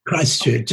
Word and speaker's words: Christchurch: Can you Christchurch.